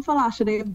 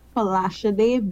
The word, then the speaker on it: Folasade: I am Folashade Bishop. That is F-O-L-A-S-A-D-E, Folashade Bishop.